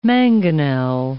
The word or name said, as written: mangonel